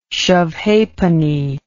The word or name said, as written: shove-ha'penny